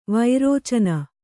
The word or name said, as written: ವಿರೇಚನೆ